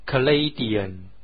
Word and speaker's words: Canadian: 它的形容词呢，就是 Canadian，也表示加拿大人，Canadian， Canada， Canadian。